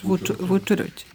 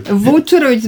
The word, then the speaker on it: Vučurović: Da, evo, gospodine, kako veš, Vučurović. – Vučurović. – Vučurović, zbog akcenta se stalno se brkam.